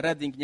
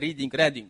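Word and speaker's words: Redding: Miejscowość się wymawia Reading, nie Reading.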